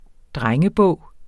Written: drengebog

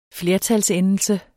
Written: flertalsendelse

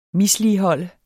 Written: misligholde

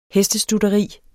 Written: hestestutteri

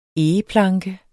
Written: egeplanke

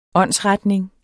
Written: åndsretning

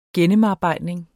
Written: gennemarbejdning

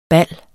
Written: ballet

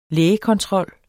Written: lægekontrol

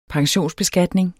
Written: pensionsbeskatning

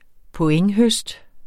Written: pointhøst

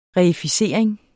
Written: reificering

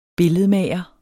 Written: billedmager

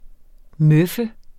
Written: møffe

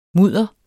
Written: mudder

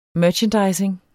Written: merchandising

